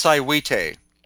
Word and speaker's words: Saihuite: I was just watching this video you have up of, I can't pronounce it, S-A-I-H-U-I-T-E. – That's Sihuite.